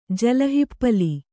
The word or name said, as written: ಜಲಹಿಪ್ಪಲಿ